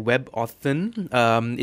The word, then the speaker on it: WebAuthn: So they've announced that there's a new thing called WebAuthn. It's spelled W-E-B-A-U-T-H-N.